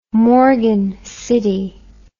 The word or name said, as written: morgan city